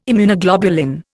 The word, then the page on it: Immunoglobulin: Indian